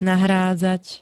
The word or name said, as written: nahrádzať